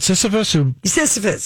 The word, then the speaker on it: Sisyphus: Is it about Sisyphus? Sisyphus. He pulled a boulder up the hill and it would roll down on him.